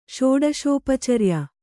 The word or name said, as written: ಷೋಡಶೋಪಚರ್ಯ